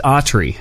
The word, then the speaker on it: Autry: It is Autry. – Okay. – Like Gene Autry without the cowboy hat.